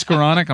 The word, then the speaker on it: Skoronek: It's Skoronek. It's Skoronek, I'm sorry. – And it was a game late. – It's a fine. – Rhymes with colonic.